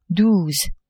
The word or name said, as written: douze